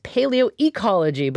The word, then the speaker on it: paleoecology: And Professor Gill, I said that wrong. It's paleoecology, but welcome to On Point.